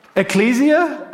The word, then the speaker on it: Ecclesia: Ecclesia? – Oh, you're wrong.